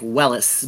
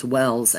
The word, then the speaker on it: Welles: His last name is W-E-L-L-E-S. Kind of looks like Wellis, but it's pronounced Wells, and such a great new artist on the scene, that's for sure.